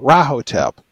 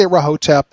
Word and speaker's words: Rahotep: But I've always gone with the accent on the first syllable, Rahotep. But you know, if you want to say Rahotep, that's okay too.